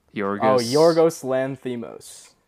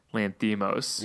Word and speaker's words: Lanthimos: Yorgos Lanthimos. – Lanthimos, yes.